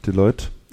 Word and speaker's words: Deloitte: Deloitte, in den Vereinigten Staaten.